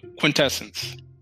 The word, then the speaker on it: quintessence: I know I messed it up in one of the other, I think it was the last show, I mispronounced it, but quintessence.